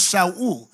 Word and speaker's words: Shaul: The Hebrew word for Saul, the Hebrew pronounce him as Shaul, it means desired.